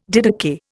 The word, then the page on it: didakai: Indian